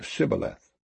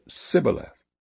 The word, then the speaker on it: Shibboleth: If he said, Nay, then said they unto him, Say now Shibboleth. And he said, Sibboleth, for he could not frame to pronounce it right.